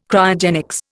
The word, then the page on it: Cryogenics: Indian